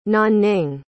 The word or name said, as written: nanning